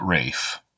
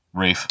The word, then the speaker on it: Ralph: I looked it up, and there's an article on BBC America that he pronounces it Rafe to rhyme with safe. – Rafe. – Yes, Rafe Fiennes. Yeah. – Rafe Fiennes. – That's how he says it's pronounced. Yeah. – Does he know this is an L in his name? – It's a silent L.